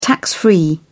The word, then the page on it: tax-free: British English